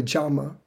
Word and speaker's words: yama: Now, one thing about the pronunciation of that word, I'm going to have to let you know this now, but when in Sanskrit, when a Y is in the beginning of a sentence or a word, it's pronounced like a J. So yoga is really yoga. And yama, which is the first part of this, is really jama.